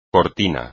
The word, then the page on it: cortina: espagnol